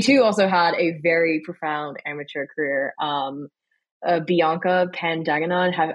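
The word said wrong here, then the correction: Pagdanganan: am I saying that right? I really hope so. I hope I just did not butcher the hell out of her name. – I wouldn't say you butchered the hell out of it, but I will jump and say it's Pogdenonen.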